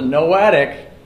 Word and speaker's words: noetic: So in proper theology, we understand that when we talk about the noetic, and it's not spelled like Noah, but an ark, it's N-O-E-T-I-C.